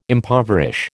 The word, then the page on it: impoverish: American English